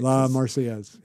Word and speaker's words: La Marseillaise: Les Marseillaises, is that, I'm not saying it right. – La Marseillaise. Yeah.